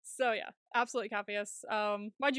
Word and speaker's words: Capheus: Did I say his name right? Then because that was why I was questioning. Cappius. – Yes. Yeah, C A P H E U S. Cappius. – C H P H. – C C A. – C A P H. – P H E U S. – E U S. So Cappius.